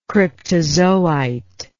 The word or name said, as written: cryptozoite